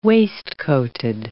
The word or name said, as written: waistcoated